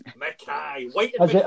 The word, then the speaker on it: McKay: Is it McKay or McKay? Because this could be quite Scottish, actually. Scott, how do you say your surname? – It's McKay. – McKay. – As in the prison governor from Porridge.